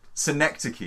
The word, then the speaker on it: synecdoche: Yeah, your pronunciation as ever is imperfect, but yes. – Cynic Docky is not imperfect, because I've looked at... – Synecdoche is the name of that. – Synecdoche, I'm sure.